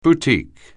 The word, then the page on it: boutique: anglais